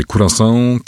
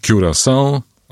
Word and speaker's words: Curaçao: Są bardzo różne wersje. Curaçao, Curaçao albo Curaçao.